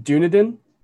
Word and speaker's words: Dunedin: I believe it is pronounced Dunedin because the name comes from the Scottish Gaelic name for Edinburgh, Dune Aiden.